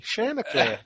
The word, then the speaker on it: Chanticleer: Chanticleer.